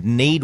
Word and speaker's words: Kneed: but Need Wallaby Grass, it's spelled K-N-E-E-D, Wallaby Grass.